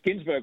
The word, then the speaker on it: Ginsberg: It's Ginsberg, like the poet.